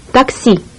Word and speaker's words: Такси: Такси. – Такси. – Такси. Notice the letter E. It's pronounced like the double E in B.